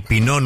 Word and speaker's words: Pinot: El Pinot Noir no viene del pino. – No. – Aunque suene. – Claro. – Porque ese pino termina con una T final. – Claro. Claro. – Que no se pronuncia.